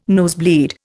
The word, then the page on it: nosebleed: Indian